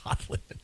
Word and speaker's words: Don Lemon: What are your thoughts on Don Lemon, who rhymes with women, by the way?